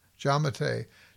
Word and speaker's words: Giammattei: Giammattei.